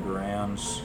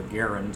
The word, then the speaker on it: Garand: In Normandy, carrying M1 Garands, or Garand is actually the true term of how it's pronounced.